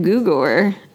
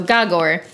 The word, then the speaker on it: Gogor: I was saying it Gogor for a long time, but thankfully the author left me a little note telling me exactly how to say it. – Gogor. Even though it's go-go. – Go-go. So Gogor.